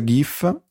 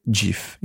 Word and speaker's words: GIF: Abbiamo parlato di SMS GIF, che tra l'altro si dice GIF in realtà, lo sai? – Boh, infatti ho detto GIF. Ma vabbè, però comunque non GIF. Credo ci sia una pronuncia ufficiale.